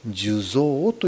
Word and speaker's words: 寿像: 実は生きているうちに作ったその姿を寿の像と書いて寿像というんです。